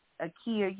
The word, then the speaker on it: Akeea: You could also find me by name, Maya Akia Yeaboa, on Facebook. – It's spelled M-A-A-T. My middle name is Akia, A-K-E-E-A.